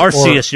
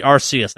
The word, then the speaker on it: Arceus: Acreus is back, y'all. You can catch a god in this game. – Is it Arceus? – Arceus, you're right. Arceus, that's the correct way of pronouncing it.